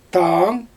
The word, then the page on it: taan: Xyzyl